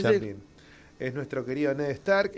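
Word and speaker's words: Sean Bean: Xian Vean se escribe y es Xian Vean.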